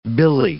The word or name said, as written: billy